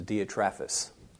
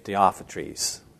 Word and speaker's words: Diotrephes: I refer to him, I pronounce his name as Diotrephes. Some will say Diophantus. But I say Diotrephes, same guy.